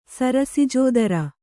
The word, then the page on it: ಸರಸಿಜೋದರ: sarasijōdara